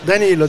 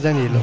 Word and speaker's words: Danilo: Puisque euh, c'est Daniel Fagioni, je ne sais pas si je prononce comme il faut. Danilo, Danilo.